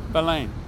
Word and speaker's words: Belém: How do you say it? – Belém. – Belém. – Belém. – Belém. – You just Belém. – Like barely close your mouth.